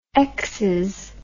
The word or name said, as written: exes